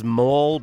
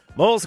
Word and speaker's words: M01E: This one I've been pronouncing as Mole, but you spell it M01E. Mole,